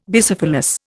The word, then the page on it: Bicephalous: Indian